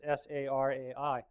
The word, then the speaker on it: Sarai: And on the internet, I was trying to figure out how you pronounce Sarai, because it's Sarai.